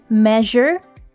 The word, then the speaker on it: measure: The word measure, you see the S here, but it's not a sh sound, it's actually a z sound. Measure. Measure. And the word Europe, R-O-P-E, is not pronounced as rope, but rip.